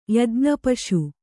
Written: ಯಜ್ಞಪಶು

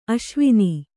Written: ಆಸವನ

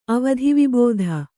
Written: ಅವಧಿವಿಬೋಧ

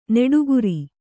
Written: ನೀಟುಗಾರ

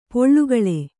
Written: ಪೋಳ್ಗಳೆ

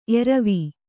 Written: ಏರುವೆ

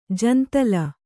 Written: ಜಂತಲ

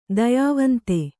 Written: ದಯಾವಂತೆ

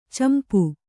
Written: ಚಂಪೂ

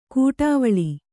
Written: ಕೂಡಾವಳಿ

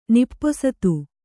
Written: ನಿಪ್ಪೊಸತು

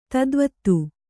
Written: ಧಾತುವಾದ

